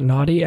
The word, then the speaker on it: Nadi: Do you know how to spell that? Nadi? N-O-D-D-I? – N-A-D-I. – Nadi. N-A-D-I. Okay. – Nadi. Okay. N-A-D-I-S. Nadis.